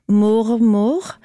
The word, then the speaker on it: mormor: You use this word, and I'm going to pronounce it in Norwegian. – Yeah, I might have butchered it. – No, it's fine. It's mormor, which means mother's mother.